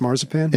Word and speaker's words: marzipan: Marzipan is pronounced marzipan? – In English.